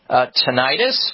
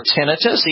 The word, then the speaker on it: tinnitus: Well, it is true that that's where tinnitus or tinnitus, either way, is pronounceable correct.